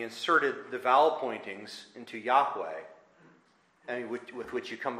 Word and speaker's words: Yahweh: His name is Yahweh. And that's the correct understanding and the correct pronunciation of it.